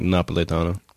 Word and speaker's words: Napolitano: Isn't the actual Italian like Napolitano? – Yes. – Napolitano. – Very nice. Well done. – Napolitano.